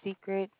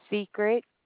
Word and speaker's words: Secret: My name is Secret. I am the supervisor of the fraud department. How may I help you? – Your name is what? – Secret. – How do you pronounce that? – Secret. – S-E-C-R-E-T. S-E-C-R-E-T.